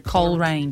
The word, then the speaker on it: Coleraine: Is that how you pronounce it? Is it Coleraine? Just Coleraine. – Coleraine. There you go.